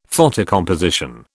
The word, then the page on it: photocomposition: British English